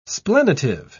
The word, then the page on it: splenitive: British English